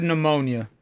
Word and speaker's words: pneumonia: Say the word pneumonia. – Me. – He's right. – No, check so. – You can't even because you can't pronounce the P. You know it's there, but you just gotta go.